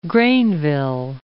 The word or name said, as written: Greynville